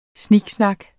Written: snak

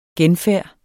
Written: genfærd

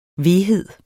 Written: veghed

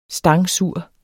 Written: stangsur

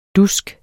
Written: dusk

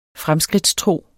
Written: fremskridtstro

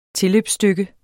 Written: tilløbsstykke

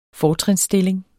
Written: FORTRINSSTILLINGERNES

